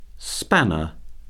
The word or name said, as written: spanner